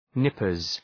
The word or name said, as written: nippers